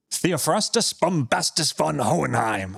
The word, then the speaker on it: Theophrastus Bombastus von Hohenheim: Yeah, say it right. – You say it right. – Theophrastus Bombastus von Hohenheim. – Yes, that's excellent.